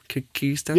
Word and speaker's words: Kyrgyzstan: Kyrgyzstan? – Yeah.